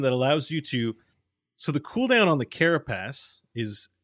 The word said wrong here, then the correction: carapace: Carapace. – Yeah. Carapace. – Yeah, I mean, you were close. You put emphasis in a weird way that made it seem dirty.